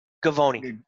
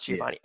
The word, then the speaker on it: Givony: Givony. Givony, wow. something like that. I, I it's spelled, I think it's spelled Givony, but I thought it was pronounced Giovanni.